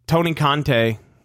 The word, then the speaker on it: Conte: Tony Keontae. I'm saying that wrong. It's with a C. Tony Conte. I think that's it.